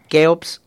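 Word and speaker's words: CHEOPS: Bueno, el KEOPS hay que decirlo que se escribe con CH porque...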